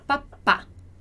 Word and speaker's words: papá: E aqui, na palavra papá, a gente tem o papá com acento, porque lembra daquela regrinha de quando a sílaba tônica é a sílaba forte, ali é a última sílaba da frase. No italiano, a gente acentua. Então, a gente coloca o acento grave ali, né? O acentinho pra cá, para poder indicar o som aberto. Então, papá. Papá.